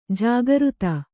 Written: ಜಾಗೃತ

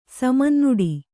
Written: ಸಮಂನುಡಿ

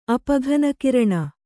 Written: ಅಪಘನಕಿರಣ

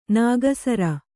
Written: ನಗಸಾರ

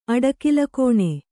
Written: ಅಡಕಲುಕೋಣೆ

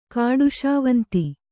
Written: ಕಾಡುಶಾವಂತಿ

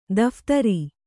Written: ದಫೇದಾರ

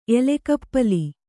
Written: ಎಲೆಗಪ್ಪಲಿ

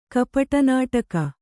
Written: ಕಪಟನಾಟಕ

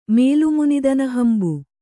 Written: ಮೇಲುಮುನಿದನಹಂಬು

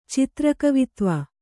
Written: ಚಿತ್ರಕವಿತ್ವ

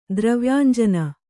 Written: ದ್ರವ್ಯಾಂಜನ

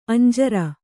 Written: ಅಂಜೂರ

